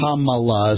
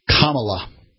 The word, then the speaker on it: Kamala: Kamala is how they want you to pronounce it. Kamala. – Okay. – Like a Kamala.